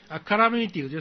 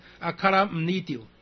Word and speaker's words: 阿卡拉姆尼丢: 阿卡拉米丢就是红死，我们念阿卡拉米丢，其实正确的发音是阿卡拉姆尼丢，阿卡拉姆尼丢是比较不好听啦，就是发音比较稍微难一点，但是通常我们大家上面也是。